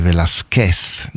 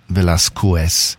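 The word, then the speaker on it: Velasquez: I do speak just a little Spanish, and I know that the correct pronunciation is Jaque Velázquez, not Velázquez.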